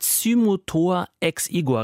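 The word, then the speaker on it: Cymothoa exigua: Zymotor ex igua, richtig ausgesprochen? – Richtig ausgesprochen.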